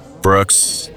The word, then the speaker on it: Brooks: O-L-I-V-E. – What is your last name? – Brooks, B-R-O-O-K-S.